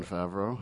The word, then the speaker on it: Favreau: The person who directed it was Jon Favreau. I don't know how to say his name. – Jon Favreau? – Yes, that's it.